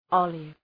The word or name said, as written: olive